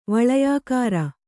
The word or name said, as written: ವಳಯಾಕಾರ